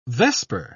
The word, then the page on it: vesper: British English